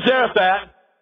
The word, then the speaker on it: Zarephath: He said, "Arise, go to Zarephath." In Hebrew, you don't pronounce the last H.